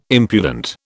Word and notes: impudent: British English

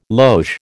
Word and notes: loge: American English

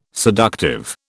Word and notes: seductive: British English

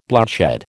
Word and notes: bloodshed: American English

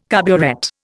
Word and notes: carburet: Indian

